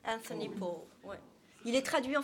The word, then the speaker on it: Powell: Powell, P-O-W-E-L-L, but it's pronounced Powell.